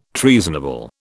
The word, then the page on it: treasonable: British English